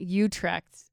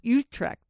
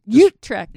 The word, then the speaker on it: Utrecht: Utrecht. Not Utrecht. That's what we thought it was, but we were corrected that the right pronunciation is Utrecht. – Yeah, say it again. – Utrecht.